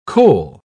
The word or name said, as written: cole